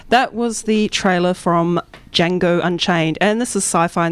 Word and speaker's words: Django: D-J-A-N-G-O. D is silent.